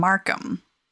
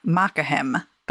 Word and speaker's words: Markham: Tom was actually from Markham. And my Witcher brain wants to pronounce that Makahem, but that's not right.